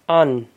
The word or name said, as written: An